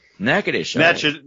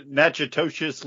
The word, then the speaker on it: Natchitoches: Natchitoches. – Natchitoches, Louisiana.